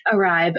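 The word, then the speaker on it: Iribe: I hope I'm pronouncing that. – Uribe, right. – Yeah. – Uribe, okay.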